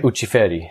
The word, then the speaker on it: Ucciferri: Ucciferri.